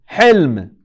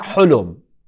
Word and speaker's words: Hilm: Hilm. And what does Hilm mean? Patience. No, not Hulum. In Arabic, that's called Hulum, the dream. Hilm means patience.